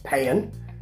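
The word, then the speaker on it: paean: Now we get the nicest piece of the programme, which is a pay-an. Look it up, pay-an. P-A-E-A-N. A sort of valedictory piece, if you like.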